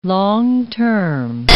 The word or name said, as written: long-term